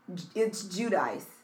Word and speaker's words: Giudice: Because, like, Teresa on The Housewives, it's spelled like that, but it's Giudice.